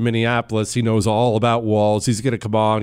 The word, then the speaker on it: Walz: I keep calling him Tim Walz and Walz, and I just have to remember it rhymes with balls. So Tim Walz, balls, Walz.